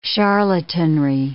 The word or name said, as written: charlatanry